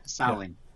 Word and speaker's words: Samhain: we call it Samhain. It would be the Gaelic pronunciation.